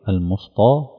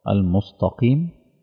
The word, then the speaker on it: المستقيم: هتلاقي في ناس بتقول على التاء طاء، تعرفوا حد بيقرأ كده المصطى المصطى المستقيم، في ناس بينطقوها كده، فأنا متوقع ان ده الأخطاء اللي ممكن تحدث، فتوقع الكلمات اللي غامضة.